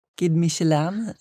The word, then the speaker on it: Guide Michelin: Och uppenbart så var det ju ett vinnande koncept för er, med tanke på att ni fick en stjärna också i Guide Michelin.